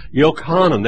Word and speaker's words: Yochanan: This is Yochanan. That's the way we would say his name in Hebrew.